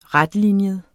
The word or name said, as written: retlinjet